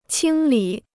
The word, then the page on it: 清理: qīng lǐ